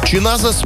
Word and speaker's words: чиназес: Слово произносится с акцентом на второй слог. При этом его следует растягивать. Правильная интонация звучит как «чиназес», где ударение падает на «а» в середине слова.